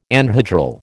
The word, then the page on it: anhedral: American English